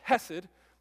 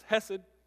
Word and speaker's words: Hesed: It's, it's Hesed. And so it's a Hebrew word written in English for you to pronounce Hesed.